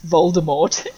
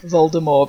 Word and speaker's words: Voldemort: You know, it's like how Joe Rowling pronounces Voldemort. All of us in the mainstream saying it how we've seen it on the movies and pronouncing the hard T, whereas she says it Voldemort because it's, you know, in her head it's from the French and that's how it should be said.